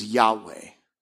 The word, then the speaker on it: Yahweh: It's four letters, and the best guess that we have, we're 99% sure that the way to pronounce it is Yahweh.